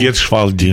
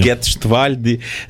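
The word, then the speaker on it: Gietrzwałd: Поміж тим, ви з паломницькими групами буваєте часто в Люрді, знову ж таки в Фатімі, в Ля-Салет, в Гетшвальді, я правильно вимовив це? Гетшвальді. – Гетшвальді.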